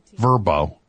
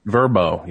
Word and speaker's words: VRBO: VRBO. Verbo. – Verbo. Yeah, I think that's how they say it.